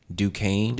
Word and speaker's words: Duquesne: I was like, Duquesne.